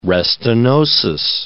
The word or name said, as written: restenosis